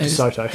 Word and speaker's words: DeSoto: DeSoti or DeSoto? – I don't know how to say it. – DeSoto. – Is it? Okay.